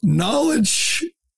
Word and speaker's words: knowledge: Knowledge over kangaroo courts. Someone has to tell Hakeem that's not an alliteration. The K is silent, Hakeem. It doesn't work.